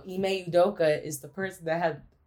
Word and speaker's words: Udoka: Oh, Udoka.